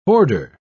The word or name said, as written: hoarder